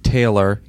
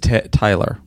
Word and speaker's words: Taylor: I have a terrible time with the lady's name Taylor. He's talking about my pronunciation of... – Bow. – He says Tyler, which is a man's name.